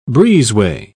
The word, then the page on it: breezeway: British English